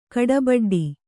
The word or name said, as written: ಕಾಡುಬುಡ್ಡೆ